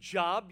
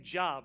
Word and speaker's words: Job: It is not pronounced Job. You're like, I've always called that thing Job, right?